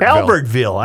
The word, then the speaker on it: Albertville: Every Thursday evening during the summer, the Albertaville. Why am I mispronouncing Albert? – Albertville. I'm sorry.